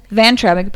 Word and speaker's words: Vayntrub: V-A-Y-N-T-R-U-B. – And he goes, Vantrub.